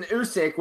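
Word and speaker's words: oosik: But so this is an Usyk, which is apparently a walrus penis bone. Am I saying that correctly? – Yep.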